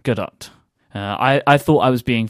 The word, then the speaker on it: Gadot: But the actress who plays Wonder Woman, her name is pronounced Gail Gadot, I assume. It's because we were telling people that we need to, people have been telling us we need to pronounce the T.